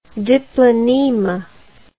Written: diplonema

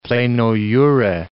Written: pleno jure

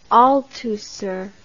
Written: Althusser

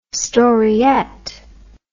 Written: storyette

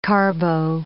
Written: carbo

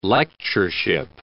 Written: lectureship

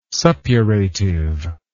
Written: suppurative

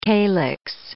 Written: calyx

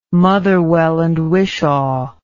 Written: motherwell and wishaw

